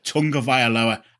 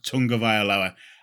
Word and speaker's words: Tagovailoa: And believe it or not, it is Tagovailoa. I don't know how because there's no N in his name, but it's pronounced Tagovailoa.